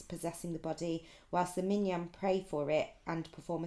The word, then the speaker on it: minyan: Yeah, not, no, it's spelled M-I-N-Y-A-N.